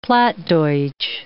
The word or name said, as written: Plattdeutsch